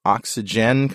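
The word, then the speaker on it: Oxygene: Mark Hoffman is the chief architect at REM Object Software and project manager for the Oxygen compiler. Is that how you say it? – Yeah. – There's an E on the end of Oxygen.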